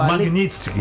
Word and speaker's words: Magnitsky: Ele pediu de novo a lei do Magistrink, né? Como é que fala isso? – O quê? – Magnitsky. Magnitsky. Ele pediu de novo a lei Magnitsky para o Xandão.